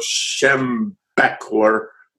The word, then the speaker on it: Schembechler: and I may pronounce his name wrong, the very famous football coach from the University of Michigan, Bo Schembechler, I think Correct.